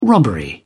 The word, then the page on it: robbery: British English